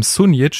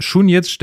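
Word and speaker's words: Šunjić: Und zwar haben wir bis jetzt immer äh, einen Namen falsch ausgesprochen von äh, unserem Neuzugang im zentralen Mittelfeld, nämlich Schunjic. Er heißt nicht, wie wir immer gesagt haben, Sunjic, sondern er heißt Schunjic, denn er hat ja dieses Dach auf dem, auf dem S.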